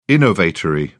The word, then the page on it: innovatory: British English